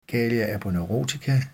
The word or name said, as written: galea aponeurotica